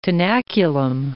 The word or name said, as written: tenaculum